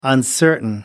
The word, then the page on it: uncertain: American English